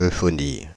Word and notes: euphonie: /ø.fɔ.ni/; [ø.fɔ.ni]

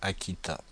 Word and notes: Aquitain: France (Paris); /a.ki.tɛ̃/

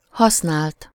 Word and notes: occasion: France; [ɔ.ka.zjɔ̃]

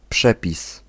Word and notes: rule: American English; /ruːl/

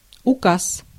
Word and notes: указ: /ʊˈkas/

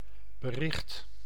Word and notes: news: /n(j)uːz/; [njuːz]

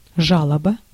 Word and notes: жалоба: /ˈʐaləbə/; žaloba

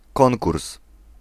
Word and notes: competition: /ˌkɒmpəˈtɪʃən/; /ˌkɑːmpəˈtɪʃən/

